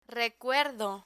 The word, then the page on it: recuerdo: Spanish